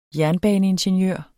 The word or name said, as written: jernbaneingeniør